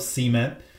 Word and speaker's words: cement: Out here, we have more of a stress on the second syllable of cement. So when I heard you say the title, I didn't hear the T of the word and your stress more of the first syllable.